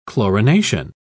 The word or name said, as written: chlorination